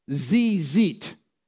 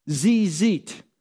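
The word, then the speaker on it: tzitzit: The woman touched the, and this word is actually pronounced zizit, zizit, the fringes on the tallit, that's the prayer shawl of Jesus.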